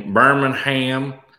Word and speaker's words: Birmingham: Birmingham. – Yeah, there's no G. Do not pronounce the G. Silent.